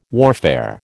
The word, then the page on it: warfare: American English